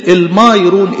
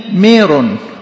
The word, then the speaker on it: ميرون: كلمة الميرون، الميم ممدودة، ده النطق العربي، لكن الكلمة ديت كلمة يوناني ميرون، الميم مكسورة، وحتى هي في الإبتي ميرون. الميم المكسورة دي ميرون، دي معناها طيب، طيب رائحة طيبة، يعني زي الروائح يعني.